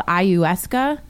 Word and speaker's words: ayahuasca: It's called Ayahuasca. I think that's how you pronounce it. Uh-huh. Ayahuasca.